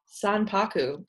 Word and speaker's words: sanpaku: Our topic this week is senpaku. Who knows if I'm saying it right? – Senpaku? – Sanpaku. – S-A-N-P-A-K-U. Sanpaku. – P-A-K-U. Okay. – Yeah, it's Japanese. – With a san.